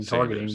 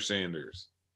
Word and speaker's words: Sanders: Targeting, but what did I say? Saunders? – Yeah. – So whatever. How do you know, by the way? It could be Saunders and everyone's mispronouncing. – They were saying it on the broadcast the whole day. – Nope, you're wrong. ESPN's wrong. – That's Holly Saunders, Spencer Sanders.